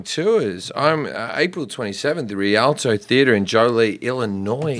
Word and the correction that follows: Joliet: Nope, it's Joliet, and you said it wrong. – Oh, fuck those people. It's this weekend. It's done now. You haven't said it right one week. – Come. Yeah. I don't want you to come if you can't pronounce your own town.